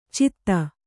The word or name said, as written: ಛದಿ